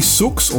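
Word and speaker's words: !Sooks: It's by I Sooks, or just Sooks. You don't pronounce it. The I is actually an exclamation mark.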